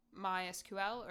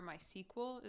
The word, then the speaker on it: MySQL: Um, and then a language that you might use to access this information is called MySQL or MySQL is often how it's pronounced. Um, but it's like M Y S Q L spelled out.